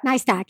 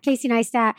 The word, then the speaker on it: Neistat: You know, I've watched videos from Casey, I can never pronounce his last name. – Neistat. – Casey Neistat.